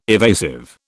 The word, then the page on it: evasive: British English